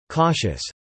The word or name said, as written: cautious